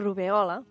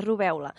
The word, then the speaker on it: rubèola: Ho he dit bé, rubèola, amb la tònica aquí, en aquesta bèola. – Sí, és perquè solem pronunciar rubèola, però no, és incorrecte, rubèola.